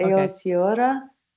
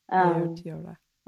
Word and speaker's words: Aotearoa: How do you pronounce the indigenous name of New Zealand? – Let's see if I can do it. Okay. Aotearoa. – Aotearoa. – Aotearoa. Close to that.